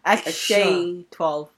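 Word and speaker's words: X Æ A-12: It's X, and then, like, the symbol is pronounced Sh. So it's like X-A-12.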